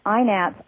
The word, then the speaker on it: INATS: And for those who are curious about it, they pronounce it INATS, I-N-A-T-S.